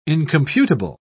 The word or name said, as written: incomputable